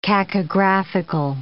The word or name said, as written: cacographical